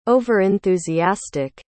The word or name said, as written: over-enthusiastic